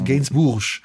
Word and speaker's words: Gainsbourg: Gainsbourg. – Kommt ja aus Frankreich, das kannst du gar nicht französisch genug betonen. – Charlotte Gainsbourg, ja.